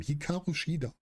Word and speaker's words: Hikaru Shida: Tony Storm gegen Hirakao Ishida. Ich kann das Wort einfach nicht aussprechen, diesen Namen. Hikaru Shida.